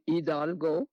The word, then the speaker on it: Hidalgo: since in Spanish the H is not pronounced, in Hidalgo, and so it came out Goliad.